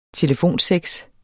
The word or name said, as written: telefonsex